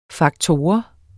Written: faktor

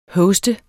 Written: hoste

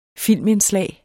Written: filmindslag

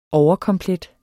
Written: OVERKOMPLET

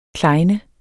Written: klejne

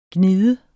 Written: gnide

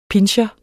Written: pinscher

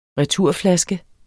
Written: returflaske

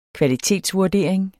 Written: kvalitetsvurdering

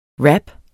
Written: rap

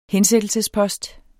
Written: hensættelsespost

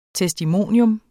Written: TESTIMONIUMS

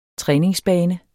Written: træningsbane

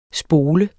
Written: spole

